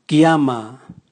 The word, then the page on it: giama: Raga